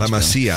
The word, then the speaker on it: La Masia: La Masia, I would say.